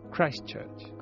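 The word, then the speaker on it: Christ Church: Christ O Church, ¿cómo se dice? – Christchurch. Eso, gracias.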